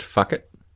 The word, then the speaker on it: Phuket: It's pronounced fuck it. Some people do pronounce it fuck it, yes. But you know what? For this little ditty, we'll call it Phuket.